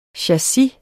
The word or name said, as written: CHASSISER